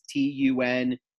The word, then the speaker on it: Tunguz: I'm going to butcher his last name. Tunguz. It's Tunguz.